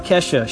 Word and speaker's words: Kesha: So, a few things about Kesha. She used to spell it K-E-$HA. Kesha, the dollar sign being used for the sound S, in which now she spells it normally, and Kesha is actually her real name, K-E-S-H-A.